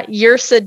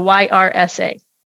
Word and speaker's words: Yrsa: So, there's a poet, I, I think you pronounce her name uh, Yursa Daily Ward, Yrsa, Y R S A. So, I believe it's Yrsa. If it's not, somebody can correct me.